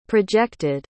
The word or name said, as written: projected